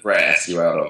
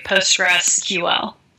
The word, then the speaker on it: Postgres: Like PostgreSQL or Postgres. – Right. Well, the canonical pronunciation at this point is Postgres. And if you want to add the SQL at the end, people say PostgreSQL.